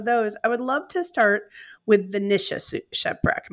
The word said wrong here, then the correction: Nicaea: Am I saying that properly, Nicias? – Nicaea. – The Nicaea shipwreck.